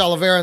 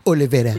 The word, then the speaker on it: Oliveira: That's why I liked Oliveira in that last fight. – You need to repronounce that. – That's right. It's Oliveira.